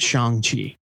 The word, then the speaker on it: Shang-Chi: And they got a note from Marvel saying it's pronounced Shang-Chi.